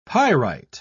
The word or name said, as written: pyrite